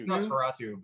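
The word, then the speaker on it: NOS4A2: I never know what that's supposed to be. Nosferatu. – It's Nosferatu, but it's spelled with numbers.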